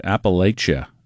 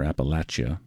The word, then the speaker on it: Appalachia: On Folk Alley, and this is Emily Scott Robinson and Appalachia, or Appalachia, just kind of depends on where you're from is how you say it, I think.